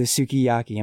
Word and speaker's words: sukiyaki: TJ writes, "The shabu shabu is divine, but I come for the sukiyaki and stay for the sukiyaki."